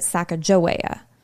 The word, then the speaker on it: Sacagawea: But the former, they pronounce it Sacagawea, the way I've been pronouncing it. And the latter pronounces it Sacagawea, which is, I would argue, probably the most popularized way.